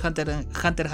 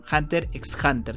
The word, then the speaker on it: Hunter x Hunter: No sé por qué la gente cuando yo empecé a ver el anime, todos le pronunciaban Hunter x Hunter, ¿sabes? Porque hay una X en medio y después me enteré que era Hunter Hunter por una amiga que me dijo qué porquería estás hablando, se pronuncia así.